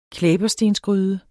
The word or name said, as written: klæberstensgryde